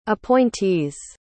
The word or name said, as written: appointees